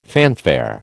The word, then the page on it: fanfare: American English